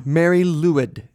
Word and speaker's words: Mari Lwyd: Mary Lewid is, I think, how you pronounce it. M-A-R-I-L-W-Y-D.